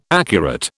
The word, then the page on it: accurate: British English